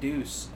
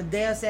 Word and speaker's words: Deus: I would have pronounced it Deuce, as in like D-E-U-C-E. – No, see, I always think of it, like Deus Machina.